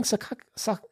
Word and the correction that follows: Sasaki: His name's Sasaki. I've been saying his name wrong the whole time. It's Sasaki. Sorry.